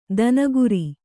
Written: ದಿನಕರ

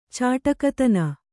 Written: ಚಾಟಕತನ

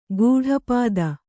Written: ಗೂಢಪದ

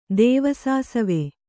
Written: ದೇವಸಾಸವೆ

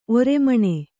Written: ಒರ್ಮಾನ

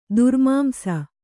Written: ದುರ್ಮಾಂಸ

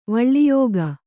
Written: ವಳ್ಳಿಯೋಗ

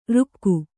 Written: ರೋಗಿ

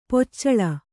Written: ಪೊಚ್ಚಳ

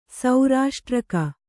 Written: ಸೌರಾಷ್ಟ್ರಕ

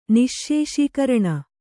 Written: ನಿಶ್ಶೇಷೀಕರಣ

